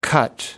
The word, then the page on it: cut down: American English